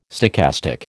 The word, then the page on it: stochastic: American English